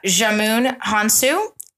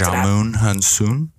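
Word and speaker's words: Djimon Hounsou: So this starred Leonardo DiCaprio, Jennifer Connelly, Jamoun Hounsou, and Michael Sheen.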